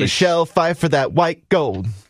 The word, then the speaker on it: Pfeiffer: Michelle Pfeiffer. – You don't think P. – Silent P.